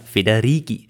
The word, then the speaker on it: Federighi: Der Nachname ist ja italienisch, also müsste er wie Lamborghini oder Spaghetti, oh, also wie Lamborghini oder Spaghetti ausgesprochen werden. So, also ohne Sch, sondern Federici.